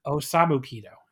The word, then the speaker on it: Osamu Kido: This is Akitoshi Saito, Kunayaki Kobashi, and the Great Kabuki defeating Akira Nogami, Takayuki Iizuka, and Osomu Kido in 13-12. I totally butchered that. in 1312. – I commend you for trying. – Osamu Kido is what I should have said in 1312.